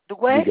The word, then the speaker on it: Duguay: So that's D-U-G-A-Y? – D-U-G-U-A-Y. – U-A-Y. – Yeah, two U's. D-U-G-U-A-Y. Yep. – Okay. And would you pronounce that for me? Duguay? – Duguay.